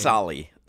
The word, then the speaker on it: Solly: I think it's Solly. Solly. – Salo, Solly, you know. – No one calls him Solo. You tweeted it out. I called you out on it on Twitter. And you spell it S-O-L-O, which sounds like you're calling him Solo.